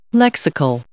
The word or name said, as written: lexical